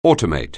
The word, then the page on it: automate: anglais